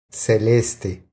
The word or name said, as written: Celeste